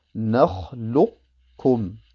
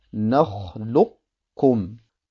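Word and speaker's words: Nakhlukum (نَخْلُقْكُمْ): Das Qaf wird also sehr wohl ausgesprochen und erhält auch seine entsprechende Zeit. Es wird allerdings nicht verschmolzen in das Kaf. Man sagt also Nakhlukum, Nakhlukum.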